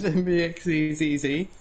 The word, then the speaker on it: Mvxzzz: Yep, my name's Mvxzzz. – Zzzz. – Zzzz, that's how I pronounce it.